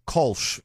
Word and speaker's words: Kölsch: Sorry, Kölsch. Let me pronounce it the way that you... It's got umlauts on it. It's German. Come now.